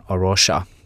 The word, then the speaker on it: A Rocha: Joo, tässä tosiaan avasi, että Arosha, tämä ehkä suomeksi voisi vielä avata, että tämä on suomalaisesti varmasti aika vaikea nimi, eli kirjoitetaan A-R-O-C-H-A, lausutaan englanniksi tosiaan Arosha, alun perin Portugalia, ja tarkoittaa kalliota.